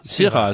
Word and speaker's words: Syrah: Syrah, as they say. Accent on the si and not the ra.